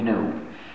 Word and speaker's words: GNU: Please pronounce it GNU. If you talk about the new operating system, you'll get people confused. We've been working on it for over 20 years now, so it's not new anymore.